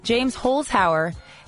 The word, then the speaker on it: Holzhauer: James Holzhauer.